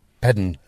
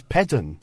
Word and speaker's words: Pedn: We had this extra sound built in. It only happened when the syllable was stressed. So you'll get Penzance, but you'll get Pedn and Les.